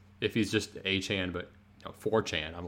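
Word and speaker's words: Achane: With all the speed, like that's kind of a boost for, you know, I would have to say A. Chan and Mostert. Apparently Devon came out and said that he wants his name pronounced A. Chan instead of A. Chain.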